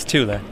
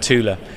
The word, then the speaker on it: Thule: Is that the correct pronunciation? – That is correct. Yes, it is not Thule. It is Thule. Yeah. – Thule.